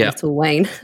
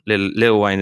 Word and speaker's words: Lil Wayne: George, our producer, is currently cracking up at pronouncing it Little Wayne. – Yeah. I've insisted on calling Will.i.am William all weekend. and Little Wayne has been Little... well, Lil Wayne has been Little Wayne.